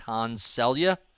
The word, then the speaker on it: Hans Selye: But there's a quote you paraphrase of Hans, I'm not sure I'm pronouncing his name right, Hans Selye? – Hans, mm-hmm, that's it.